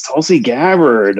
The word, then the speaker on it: Tulsi Gabbard: Yes, Tulsi Gabbard.